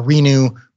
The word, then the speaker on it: RENU: So I've got a Renew cold tank. R-E-N-U is the name of their company, and they're great. – R-E-N-U? – Yeah. – Okay. – They're pronounced Renew.